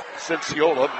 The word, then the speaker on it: Cinciola: Near side to John Cinciola. Cinciola, my mistake. Accent in the wrong syllable.